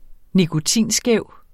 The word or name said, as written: nikotinskæv